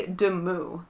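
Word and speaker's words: Demoux: I always give it the French pronunciation, which sounds weird in English, but in French it would be Demoy.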